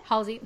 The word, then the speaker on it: Halsey: So, like, when Hasley, is that how you say her name? – Halsey? – Halsey. Yes, thank you.